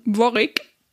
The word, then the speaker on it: Warwick: Geschrieben wird die Stadt Warwick, also quasi wie Krieg und Wick, wird aber ausgesprochen Warwick.